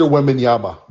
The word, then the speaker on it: Wembanyama: And then now, Wembanyama, am I saying that right? – Victor Wembanyama.